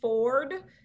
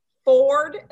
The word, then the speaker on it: Ford: If you guys just want to follow my personal Instagram, that's CCV Ford, CICI Did I say that right? See my brain gets foggy. CICIV Ford, F-O-R-D, like the car, on Instagram.